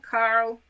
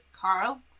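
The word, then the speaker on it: Karel: Her parents were Carl. It's K-A-R-E-L. He was, like, an immigrant. And I've heard it pronounced as Carl.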